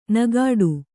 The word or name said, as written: ನಗಾಡು